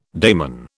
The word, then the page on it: daimon: British English